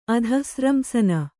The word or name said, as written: ಅಧಃಸ್ರಂಸನ